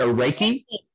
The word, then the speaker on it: Reiki: Is it Reiki on your... on your shirt? Is that how you pronounce that? Reiki. – Yeah.